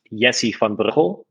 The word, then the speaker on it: Jessie van Breugel: So my full name is Jessie van Breugel. In Dutch, it's Jessie van Breugel, but I think that's pretty hard for your audience to remember or even pronounce.